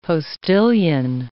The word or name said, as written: postillion